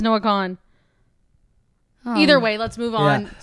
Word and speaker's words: Kahan: Per Google, I said, how do you pronounce Noah Kahan? And it's Kahan.